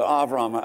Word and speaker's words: Avram: Think of the near the end of Fiddler on the Roof when we're going to go stay with Uncle Avram, Abraham, the way we pronounce it, but Avram.